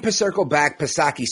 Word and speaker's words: Psaki: You see, Jen Psaki, silent P, of course, all she does is dance around this.